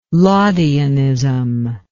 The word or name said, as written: laudianism